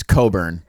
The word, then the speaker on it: Cockburn: Last week, I called Kofi Cockburn, and it's Coburn. Apparently, that CK is silent.